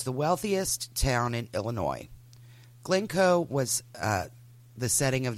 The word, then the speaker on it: Illinois: She grew up in the neighboring communities of Highland Park and Glencoe, Illinois. If you're not from, you know, the S is silent.